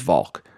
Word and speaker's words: Volk: So, I mean, the correct pronunciation of my last name is Volk, but it's going to be looking for V-A-L-K and not W-A-L-C-H.